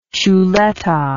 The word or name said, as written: chuleta